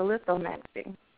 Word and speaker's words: lithomancy: Well, I just wanted a reading with the lithomancy. – Okay. You pronounced it correctly. When I first learned lithomancy, because they taught me calling stones, and the actual term is lithomancy, and a couple years later I went on a dictionary, and I was, oh, lithomancy.